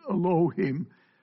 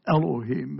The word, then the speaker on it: Elohim: This is the word I was taught in Hebrew class to pronounce it, Elohim. Most people call it Elohim, and it doesn't really matter, I guess. The Hebrew spelling makes it a schwa, which is a half vowel, and that's why you kind of skip over it quickly.